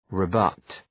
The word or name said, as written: rebut